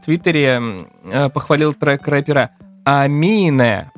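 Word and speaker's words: Aminé: Произносит его Амина, несмотря на апостроф э, в конце, что как бы предусматривает, что и на конце должно читаться. А, и вообще туда типа должно ударение падать. Но нифига. Амина.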